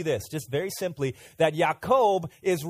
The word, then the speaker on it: Yaakov: Jacob, when you say Jacob's name in Hebrew, it's pronounced Yaakov. Okay, can you all say that? 1, 2, 3. Yaakov.